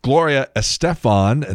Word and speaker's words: Gloria Estefan: Gloria Estefan, and that is the correct pronunciation of her name, 64.